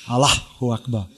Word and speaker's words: Allahu Akbar: Allahu Akbar.